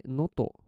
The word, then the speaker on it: gnoto: ノトこれ結構英語だとなんか普通発音できなそうなんですが、 GNOTO で o とって読むんですね。